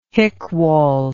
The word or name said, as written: hickwall